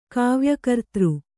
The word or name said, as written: ಕಾವ್ಯಕರ್ತೃ